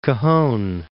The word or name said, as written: Cajon Pass